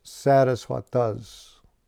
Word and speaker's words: Saraswata: And thus we are known as Saraswatas. See that with the first A, Saraswata.